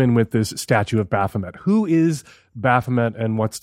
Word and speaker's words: Baphomet: There's the statue of Baphomet. Am I pronouncing that correctly? – Yes.